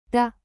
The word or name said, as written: de